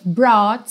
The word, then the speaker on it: brought: Também não se deixe confundir aí por esse GH, para quem está ouvindo no podcast. Brought, que é o passado e o particípio do verbo to bring, trazer. É escrito B-R-O-U-G-H-T, mas a pronúncia é bem simples. Brought. Brought ok?